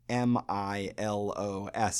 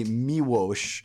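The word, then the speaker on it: Miłosz: His name is Sheshwaf Miwosh. It doesn't look like it's pronounced that way. Uh, his last name is spelled M I L O S Z, and I think most people call him Milos or Milosh. It's actually Miwosh, I hear, but I am a monolingual American English speaker, so I apologize in advance.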